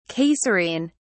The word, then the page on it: Caesarean: British English